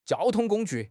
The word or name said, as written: jiao1 tong1 gong1 ju4